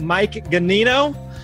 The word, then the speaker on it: Mike Ganino: I'm here hanging out with Mike Ganino. And wait a minute. Is that how I pronounce it? – Yeah, you got it.